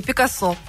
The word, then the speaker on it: Пикассо: А как все-таки, Пикассо или Пикассо? Как нужно произносить? – В нашем случае Пикассо.